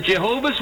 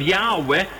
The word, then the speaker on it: Yahweh: The first one is from the New World Bible Translation Committee of the Jehovah's Witnesses, and I quote, "While inclining to view the pronunciation of Yahweh as the more correct, we have retained the form Jehovah because of the people's familiarity with it since the 14th century.